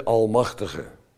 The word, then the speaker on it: Almachtige: En dan volgt die term, de Almachtige. Met de naam, met de klemtoon op al.